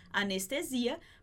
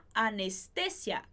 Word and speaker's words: anestesia: E pegando o gancho aí dessa ideia, a gente tem a palavra anestesia, que em português a gente fala anestesia. A gente escreve igualzinho em espanhol, só que a gente vai mudar a sílaba mais forte. Então, em português a gente fala anestesia, mas em espanhol a gente fala la anestesia. La anestesia, perfeito?